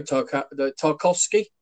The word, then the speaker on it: Tarkowski: You know, Tarkowski. Is that how you pronounce it? I think so. Tarkowski.